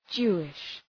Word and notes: Jewish: ‘dʒu:ıʃ

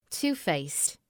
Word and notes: two-faced: ‘tu:,feıst

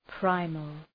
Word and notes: primal: ‘praıməl

